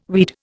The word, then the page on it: weed: Indian